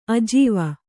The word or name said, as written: ಆಜೀವ